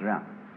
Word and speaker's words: jen: In Confucius philosophy, the fundamental virtue of a human being is called Jian, spelled J-E-N for reasons best known to Chinese scholars. I don't know what they are, but it's pronounced Jian.